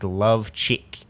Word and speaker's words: Plachy: It's pronounced love chick.